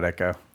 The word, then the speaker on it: Ecco: E-C-C-O. – I called it Echo. – Yeah, I mean, that is the correct way to say it. But when I was a kid, it was like, Echo the Dolphin. It's like the C-O at the end threw me off as like Co.